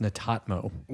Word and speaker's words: Netatmo: So it's actually pronounced Netatmo.